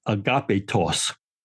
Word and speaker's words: agapitos: But the word is agapitos. So you can hear agape and just add extra syllable TOS and makes it into adjective, beloved.